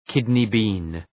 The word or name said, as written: kidney bean